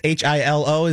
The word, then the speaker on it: Hilo: H I L O is the island. Uh, oh, Hilo. It's Hilo. Hilo. Hilo. They're in Hilo.